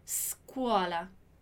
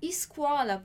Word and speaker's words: scuola: É um S mudo mesmo. Então a gente só vai dizer scuola. Scuola. Dillo chiaro? Scuola. Ok? É importante fazer esse treino porque a nossa tendência é de dizer escola, por exemplo, né?